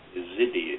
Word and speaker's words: Yazidi: Azedi, it's Y-E-Z-I-D-I. Yazidi, it's pronounced.